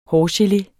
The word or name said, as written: hårgelé